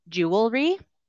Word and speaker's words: jewelry: Jewelry? Jewelry. What do you think? Okay. Perfect. Okay, so most of you have got this one right too. So it's jewelry.